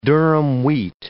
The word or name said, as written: durum wheat